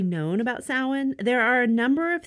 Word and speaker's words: Samhain: S-A-M-H-A-I-N.